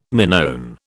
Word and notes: Minoan: British English

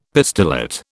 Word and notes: pistillate: British English

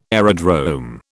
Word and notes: aerodrome: British English

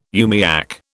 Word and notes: umiak: British English

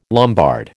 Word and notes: Lombard: American English

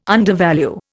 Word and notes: undervalue: Indian

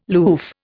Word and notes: loof: Indian

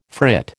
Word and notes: frit: American English

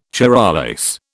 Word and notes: Charollais: British English

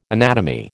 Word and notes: anatomy: American English

